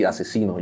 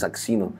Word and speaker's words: Aczino: O sea, porque no suenan así, no pero ya cuando lo, sabes y lo ves, lo lees así, asesino, ya no dices axino.